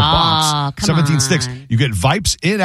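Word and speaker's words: Yipes: Vipes. Rhymes with stripe, I guess.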